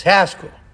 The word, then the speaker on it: Haskell: My first name rhymes with rascal. It's Haskell.